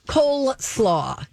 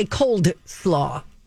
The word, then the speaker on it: coleslaw: Words that we mispronounce quite a bit that still make sense, they still kind of work, even though they're mispronounced, like coleslaw, C-O-L-E, slaw. – Yeah. – Some people say cold slaw.